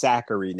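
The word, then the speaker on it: Sakkari: I'm still used to calling her Sakari, but apparently her name is pronounced Sakari now.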